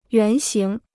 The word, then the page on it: 原型: yuán xíng